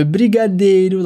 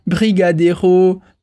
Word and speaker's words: brigadeiro: Ouais, mais je sais, là, j'ai ma prononciation brésilienne, parce qu'elle dit plutôt brigadeiro, paçoca, cocada, voilà, on essaye toujours de mettre l'accent sur la dernière syllabe en français.